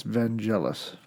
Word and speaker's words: Vangelis: Everyone, it's Vangelis.